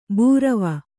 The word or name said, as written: ಭೈರವಿ